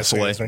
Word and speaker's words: Saleh: They went with the defensive route with Robert Saleh. Am I saying that right? – Oh, yeah, Saleh. Yeah.